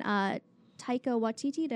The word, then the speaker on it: Taika Waititi: And you started to see a lot of that come in when Taika Waititi, did I say his name correctly? – Yeah.